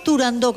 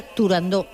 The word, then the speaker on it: Turandot: Si vemos Turandot con acento en la O, todo el mundo dirá Turandot.